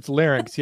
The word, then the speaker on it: larynx: Am I saying that right? Larynx? – I believe it's larynx. Yes, good job.